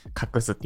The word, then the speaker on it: 画す: 隠すって読むんですね。